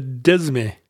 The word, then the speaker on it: disme: The dime is the tenth, but it's spelled D-I-S-M-E. – Oh, yeah, the desme. – Yeah, it's French, I guess. And you, and when you put the silent French S in, you can totally see the Latin derivation from desma or decimal. You know, that's dime just means tenth with that silent S in there.